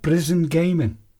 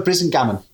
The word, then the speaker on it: Brisingamen: So now we're on to your final book, The Weird Stone of Brisingamen. – Yeah. – Have I pronounced that correctly? – No, not quite. – Go on then. – The Weird Stone of Brisingamen.